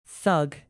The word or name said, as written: thug